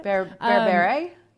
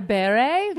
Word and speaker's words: Berbere: Like you said, you know, I started adding more, I never know how do you pronounce it? Berbere? Did I say it right? – Berbere? Yeah. – Berbere, yes.